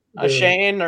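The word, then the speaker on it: Achane: Devin, is it Akine? Is that how you say it? – Ashane or.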